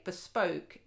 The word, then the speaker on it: Bspoke: And by the way, bespoke is spelt B-S-P-O-K-E.